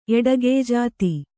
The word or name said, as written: ಎಡಗೆಯ್ಜಾತಿ